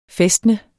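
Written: fæstne

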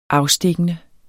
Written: afstikkende

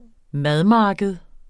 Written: madmarked

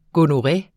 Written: gonore

